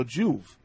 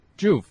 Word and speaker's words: Juve: And please pronounce my name as J as in John, U as in unicorn. So Juve. – Juve.